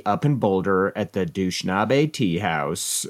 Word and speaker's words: Dushanbe: Dushanbe.